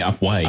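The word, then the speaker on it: Upwey: Nancy Herrett from Upwee is a passionate renewal to the station. And we have. Did I say that incorrectly? – That's too cute. I think it's originally Upway.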